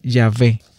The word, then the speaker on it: Yahvé: Por investigaciones recientes, los expertos han concluido que la pronunciación más probable era Yahvé.